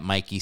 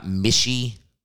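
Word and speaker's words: Michie: It's one of the most beautiful places on earth to go watch a football game at Mikey Stadium. It's pronounced Mikey, by the way, not Mishy. Fucking announcers getting paid $500,000 can't learn the correct pronunciation. It's Mikey Stadium.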